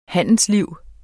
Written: handelsliv

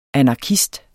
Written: anarkist

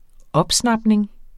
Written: opsnapning

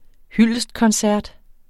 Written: hyldestkoncert

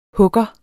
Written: hugger

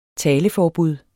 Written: taleforbud

